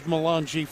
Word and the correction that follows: Valinge: Or I think it's actually Valinge. I might have mispronounced it.